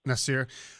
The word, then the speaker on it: Nasir: Now, first of all, Nasir, is that the right way to say your name? – Nasir. Yes, sir.